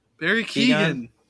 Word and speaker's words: Barry Keoghan: And I am going to butcher his name because I refuse to learn it, but Barry Keoghan? – Barry Keoghan.